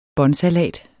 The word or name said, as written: båndsalat